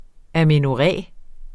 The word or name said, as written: amenorré